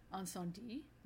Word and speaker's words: Incendies: I mean, So, this week, we are talking about, and we had watched Incendies, which I embarrassingly enough, Ricky, I was calling this or pronouncing this wrong last week.